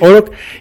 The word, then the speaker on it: Oroch: En este caso, platícanos del portafolio de unidades que tienes para el segmento de las empresas como Renault Oroch, que tú me decías que se pronuncia así, Renault ¿sí? – Oroch,